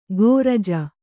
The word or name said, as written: ಗೋರಾಜ